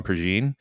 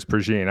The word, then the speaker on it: Prejean: First off, I had no idea it was Prejean. I've been pronouncing it Prejean for years. Like rhymes with Dijon.